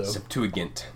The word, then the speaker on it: Septuagint: Yeah, I've heard Septuagint too, but yeah, at least I was taught that it's a hard G, so. – Septuagint.